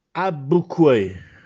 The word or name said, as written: Abbuccuè